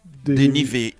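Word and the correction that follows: Olivier Deriviere: De Rivière.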